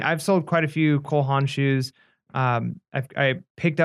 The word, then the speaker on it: Cole Haan: Am I saying that right? – No, Cole Haan, you're right.